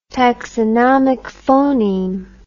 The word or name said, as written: taxonomic phoneme